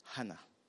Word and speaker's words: Hannah: Anna. Sorry, Hannah, my pronunciation.